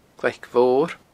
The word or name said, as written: glaicmhor